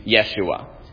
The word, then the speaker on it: Yeshua: They'll say that you have to pronounce his name Yeshua.